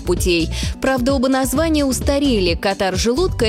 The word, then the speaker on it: Катар: Катар или Катар? Где ударение? Если речь идет о государстве на Аравийском полуострове, то, конечно, Катар с ударением на первый слог.